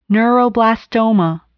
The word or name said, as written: neuroblastoma